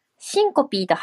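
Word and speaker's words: syncope: 私もシンコピーと発音できていなくて恥ずかしい日本人の一人でしたが、この放送をきっかけに発音修正インプットしましたとのことです。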